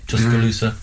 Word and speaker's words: Tuscaloosa: She was born Ruth Lee Jones in 1924 and died in 1963, born in Tuscaloosa. Is that how you say it? Tuscaloosa. Yeah.